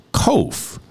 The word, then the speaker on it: kof: The verses are 145 to 152, and the Hebrew letter assigned to this section looks like a P, but it is called a kof. It is pronounced Q as in queen.